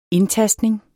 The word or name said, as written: indtastning